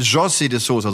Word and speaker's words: José de Sousa: José de Sousa, so wird er ausgesprochen.